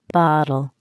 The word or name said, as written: bottle